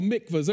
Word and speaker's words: mikvah: These little things right here are called mikvahs.